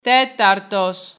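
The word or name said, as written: τέταρτος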